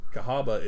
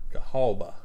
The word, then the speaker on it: Cahaba: Cahaba is C-A-H-A-B-A. And they spell the town C-A-H-A-W-B-A. That's probably an Indian Native American term. – Cahabula. – Cahaba. – Cahaba.